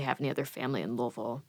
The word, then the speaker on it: Louisville: How's it pronounced properly? – Louisville. – Louisville. – Yep. It doesn't matter. – I know. I always, like, that's one of the cities in this country I always go, how do you actually say it? – Oh, yeah. It's so messed up. Doesn't make any sense. No. It's phonetically a disaster.